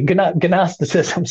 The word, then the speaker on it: Gnosticism: We know that's part of Gnosticism, which for some of our listeners who don't know Gnosticism, Gnostic, starts with a G, Gnosticism, Stan, but pronounced Gnosticism,